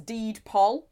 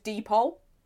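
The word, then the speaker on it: deed poll: A fan of Yorkshire Tea has shown his commitment to his favourite drink by changing his name by Deepol, which I never knew was Deedpol. I always thought it was just Deepol. Yeah, it's only now I've seen it written down. Deedpol. – Spell it. – D-E-E-D. – Space. P-O-L-L.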